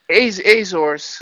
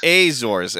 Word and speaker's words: Azores: The Azarks or Ozarks? – Azores. – Azores.